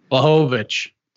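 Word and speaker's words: Błachowicz: How do you pronounce his last name? – Mahovic.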